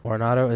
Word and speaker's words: Arenado: For third base, Nolan Arenado. – Arenado, is that a good one? – Mm-hmm.